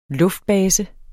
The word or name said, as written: luftbase